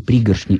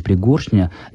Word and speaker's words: пригоршня: И «пригоршня», и «пригоршня», и, соответственно, ударение смещает, возможно, оба в косвенных падежах – это допустимые в литературном языке варианты. И то, и другое правильно.